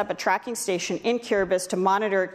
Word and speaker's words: Kiribati: Kiribati, it's spelled Kiribati, but it's pronounced Kiribati. Long story.